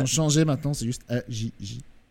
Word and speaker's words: AJJ: AJJ, comme ça se prononce, c'est littéralement trois lettres.